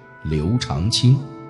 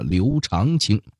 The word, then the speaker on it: 刘长卿: 田阳跟你聊聊大唐的悲催诗人刘长卿。咱们先得说说这个读音问题啊。咱们今天故事的主人公呢，现在约定俗成都被念作了刘长卿，但做这期节目的时候呢，我也认真做了一下功课，发现其实这个长字呢，其实在这里应该是念成长的，这里面的门道可不少。